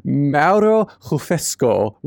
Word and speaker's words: Mauro Refosco: Mauro Refosco.